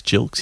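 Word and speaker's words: Gilkes: Matthew Jilks or Gilks? Jilks, he's a soft G, isn't he?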